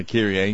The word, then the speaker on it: Kyrie: I can find a book and find out the definitions of the worship, you know, the Kyrie, I'm not even sure how you pronounce it, to be honest. – Yeah, Kyrie, yes.